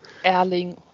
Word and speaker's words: Erling: Erling Haaland.